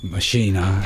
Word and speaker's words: Maschine: In the last couple of years, I've been using this Maschine, as it's pronounced in German.